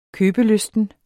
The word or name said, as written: købelysten